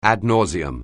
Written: ad nauseam

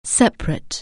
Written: separate

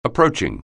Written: approaching

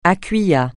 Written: à quia

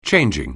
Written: changing

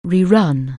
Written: rerun

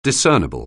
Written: discernible

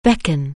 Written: beckon

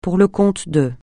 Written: pour le compte de

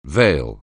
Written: veil

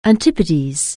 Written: Antipodes

